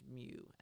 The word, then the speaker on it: mu: but I believe it's pronounced mu, M-U.